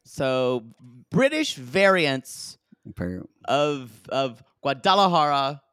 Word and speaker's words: Guadalajara: So we have a couple of Guadalajara pronunciations. Guadalajara. From Guadalajara. – Mm-hmm. – Guadalajara. Then one of her, either her sister or her mother or her friend says Guajaladara.